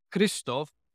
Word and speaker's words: Hristov: which is Christov,